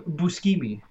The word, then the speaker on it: Buscemi: You're supposed to say it by his proper Italian pronunciation. Steve Buscemi.